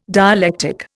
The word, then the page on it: dialectic: Indian